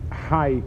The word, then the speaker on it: Hai: His name Hai comes, it's sort of the Iraqi version of Chia. The name was like Rav Chia. That's the way they pronounced it. That's where they said it.